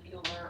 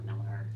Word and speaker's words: Mueller: I was Greg Cassar's appointee, and I actually live in the Mueller or Miller, depending on your preferred pronunciation, neighborhood, which is in Kansas.